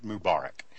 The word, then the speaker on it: Mubarak: Come on, jump in here and fix my pronunciation. – I call it Mubarak.